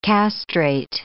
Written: castrate

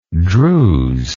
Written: druzian

